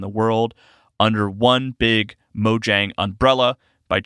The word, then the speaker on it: Mojang: Minecraft developer Mojang, which I learned is pronounced Mojang in Sweden, where the studio is based, and then in America it is pronounced Mojang.